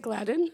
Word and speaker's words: Gladden: Are you saying his name right? – Ronald Gladden? – Is it Gladden? – Yeah.